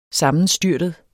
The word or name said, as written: sammenstyrtet